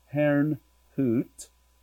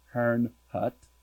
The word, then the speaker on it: Herrnhut: I led a team of intercessors to recover the anointing of the Moravian Prayer Watch that had been held in Herrnhut. That would be more proper pronunciation. We say Herrnhut.